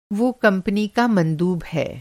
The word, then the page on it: मंदूब: مندوب